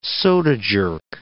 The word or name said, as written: soda jerk